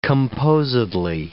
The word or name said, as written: composedly